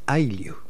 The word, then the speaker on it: Ailiu: Es Ailiu, sin acento en la U.